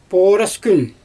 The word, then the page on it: pooras kün: Xyzyl